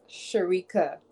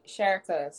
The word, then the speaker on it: Sherika: My name is actually pronounced Sherika. But when Bajans talk, is be like Sherika. So I just answer to both.